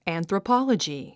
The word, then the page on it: anthropology: æ`nθrəpάlədʒi; アンスラポロジィ